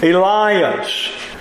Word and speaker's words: Elias: Elias, the Greek pronunciation of the Hebrew name Elijah.